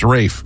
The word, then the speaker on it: Ralph: Pronounced Rafe, even though it's spelled Ralph.